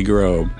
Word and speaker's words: Grob: It's definitely Grob.